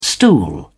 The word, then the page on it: stool: British English